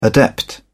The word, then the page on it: adept: British English